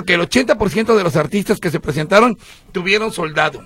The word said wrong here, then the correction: sold out: ¿Cómo soldado? ¿No se dice así, soldado? – Sold out.